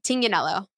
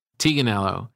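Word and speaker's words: Tignanello: T-I-G-N-A. Yeah. – Yeah. How do you pronounce it? – Yeah. Taganello. – Taganello.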